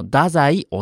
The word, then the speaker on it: 太宰: はい、だから全く違うこの太宰治というペンネームを考えたそうですよ。 – ということは、青森弁、津軽弁っていうのかな、それでは太宰はそのまま太宰というふうに発音するんでしょうか？ – あ、はい、そうらしいです。